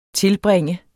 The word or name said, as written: tilbringe